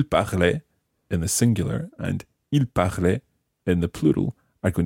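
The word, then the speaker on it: parlaient: And in fact, the il plural form is spelt P-A-R-L and the ending is A-I-E-N-T. Il parlait. – Il parlait. – So, again, there's a silent E-N-T at the end of the il form.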